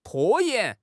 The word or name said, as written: 拖延